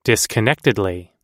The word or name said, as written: disconnectedly